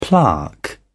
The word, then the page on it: plaque: British English